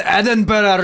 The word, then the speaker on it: Edinburgh: Did I pronounce Edinburgh? Edinburgh. I think it's Edinburgh. – Edinburgh. Edinburgh. Thank you. – That's Edinburgh.